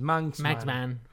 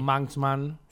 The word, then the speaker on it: Manxman: Sí, The Manxman no es tan difícil. Manxman. Es como Mad Max, pero sí. Manxman.